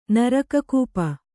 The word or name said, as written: ನರಕಕೂಪ